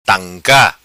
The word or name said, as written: Tanca